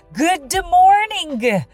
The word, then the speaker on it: good morning: 例えば、 good morning, good morning と書くけれど、 good morning とは発音せず、 good morning わかります ?good morning つまり good の d と morning の g は発音しない。